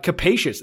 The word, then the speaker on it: capacious: Let me see how to say this. Capacious. That is exactly how you pronounce it, by the way.